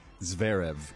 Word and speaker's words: Zverev: Zverev.